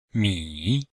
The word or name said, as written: mi3